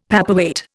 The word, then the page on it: paperweight: Indian